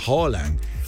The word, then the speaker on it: Haaland: Se pronuncia Haaland.